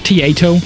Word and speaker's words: Taito: It's pronounced Taito.